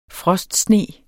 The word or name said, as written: frostsne